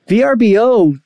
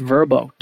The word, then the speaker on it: Vrbo: Although VRBO, they came out and were like, no, our name is pronounced Vrbo. Dude, no one calls you Vrbo.